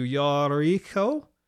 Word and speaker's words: Jericho: By the way, Jericho, that's the English pronunciation, but in Hebrew it would be closer to Yariko, which very similar to the moon, Yariach.